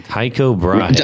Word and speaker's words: Tycho Brahe: Tycho Brahe.